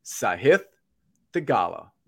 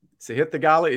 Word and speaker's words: Sahith Theegala: His name is Sahith Theegala. Sahith Theegala is your first round leader at the Mexico Open at Vedanta.